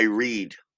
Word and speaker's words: read: But that word has two pronunciations. If you use it in the present, I am reading, or I read.